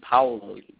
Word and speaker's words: Paolo: Most people say like Paulo, but it should be Paolo. But you have to say it kind of with like the Latin flair, you know, to really say it right.